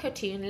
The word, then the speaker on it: Khatun: And Nazia Khatoon, let me spell it for you. N-A-Z-I-A, first name. K-H-A-T-U-N.